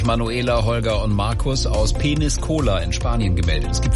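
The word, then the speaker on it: Peñíscola: Aber ich vermute, es spricht sich anders aus. – Peniscola. – Ah, Peniscola, ja, das ist natürlich was ganz, ganz anderes.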